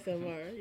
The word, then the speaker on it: ASMR: Is it AMS? What is ASMR? – It? – ASMR. Yeah. – Did I say it wrong the first time? – Yes.